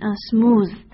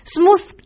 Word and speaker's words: smooth: 我们看到这个成语里面啊，这个 smooth，首先请大家注意它的发音 TH 字母组合在这里面发成浊辅音、 smooth smooth 指的是光滑和平坦的意思， rough 指的是粗糙和崎岖不平。